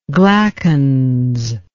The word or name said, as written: glackens